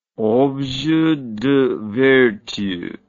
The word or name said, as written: objet de vertu